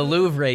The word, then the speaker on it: Louvre: The Louvre. – It's like Brett Favre.